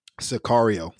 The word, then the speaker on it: Sicario: Sicario.